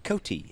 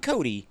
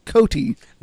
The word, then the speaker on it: Coty: C-O-T-Y. Not Cody. – No. – Cotie. – If it was C-O-D-Y, like Buffalo Bill Cody, I'd pronounce it like Cody. But it's C-O-T-Y, so I will continue to pronounce it Cotie.